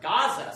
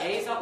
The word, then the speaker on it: Gaza: "And the border of the Canaanites was from Sidon, as thou comest to Gerar unto Gaza," or Gaza. Sometimes it will be pronounced Gaza or Gaza.